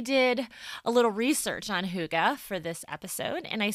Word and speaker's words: hygge: Well, hygge, which is spelled H-Y-G-G-E, is a Danish word for the art of cozy.